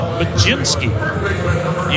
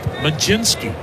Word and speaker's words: Mlodzinski: You pronounce it Majinski. You spell it like it should be pronounced Modlodzinski. M-L-O-D-Z-I-N-S-K-I. Pronounced Majinski.